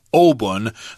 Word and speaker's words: Obon: I believe the kanji for Obon is O and then pun.As you say in Mandarin Chinese, but of course in Japanese pronunciation, it's Obon.